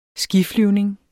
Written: skiflyvning

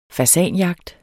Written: fasanjagt

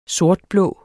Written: sortblå

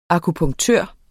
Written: akupunktør

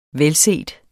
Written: velset

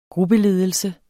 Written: gruppeledelse

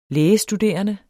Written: lægestuderende

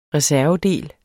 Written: reservedel

